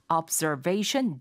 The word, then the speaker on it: observation: observe를 하는 그런 데크다라고 해서 observation deck. 라고 말하게 됩니다. – Observation deck. – Observation deck. 이거 sure. – 옵저베, 옵저, 앞저 이렇게 말씀하시면 잘 못 알아듣고요. Observe 해서 z 발음이 됩니다.